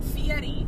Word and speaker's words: Fieri: No, because it's Guy Fieri, but he pronounces it Fieri. – But everybody, but then, I. But, so it's Fieri.